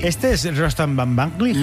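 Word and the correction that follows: Batmanglij: Casi bien pronunciado, ¿eh? O sea, tercero de Radio 3 tiene casi. – Van Banglig. – Batmanglig. – Batmanglig.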